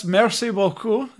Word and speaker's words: merci beaucoup: So I guess merci beaucoup. Is that right? Did I say it right? – That's pretty good. Yeah. And that's what it is.